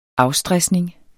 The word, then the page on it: afstresning: -ˌsdʁεsneŋ; -ˌsdʁasneŋ